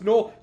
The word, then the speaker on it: Gnoll: It's pronounced Gnoll. You've only ever heard it pronounced.